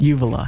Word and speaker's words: uvula: Uvula.